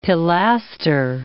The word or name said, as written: pilaster